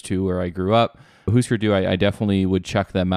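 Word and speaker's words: Hüsker Dü: I always thought it was Husker Du. I don't know how to say it. – In the documentary for this album, they pronounce it Husker Du. – Husker Du, which this shows how not cool we are.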